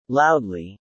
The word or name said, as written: loudly